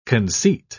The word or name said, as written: conceit